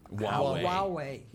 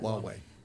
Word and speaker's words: Huawei: I think, actually, as I was driving here tonight, there was a discussion about, I don't know how to pronounce the name of this guy. – Huawei. – Yes. – Huawei.